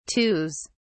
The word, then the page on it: twos: British English